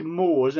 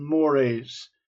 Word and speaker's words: mores: I'm not a sociologist, but it's a word that's used, and it looks like mores, M-O-R-E-S, but it's pronounced mores.